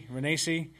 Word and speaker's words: Renacci: Renacci, can't say his name right. – Did you say Nazi by accident? – Renacci.